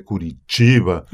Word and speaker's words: Curitiba: en la ciudad de Curitiba.